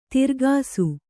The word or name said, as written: ತಿರ್ಗಾಸು